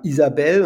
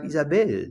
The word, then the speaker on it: Isabelle: Das Deutsche ist Pascal und die Franzosen sagen Pascal. Ja, wir sagen Isabelle und der Franzose sagt Isabelle. Der wartet ein bisschen, bis er die Betonung macht, weil er die Kraft nach hinten raus nochmal verwendet. Und es ist ja das eine nicht richtiger als das andere, aber es ist schön, wenn man sich genau damit befasst, glaube ich.